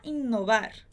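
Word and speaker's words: innovar: Então, Pablo Picasso, que é o pintor, Pablo Picasso, queria inovar. Queria inovar. Atenção aí que em espanhol, inovar tem dois N's, tá? Inovar. A gente prolonga um pouquinho mais essa pronúncia.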